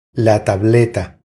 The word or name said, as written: La tableta